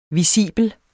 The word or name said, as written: VISIBELT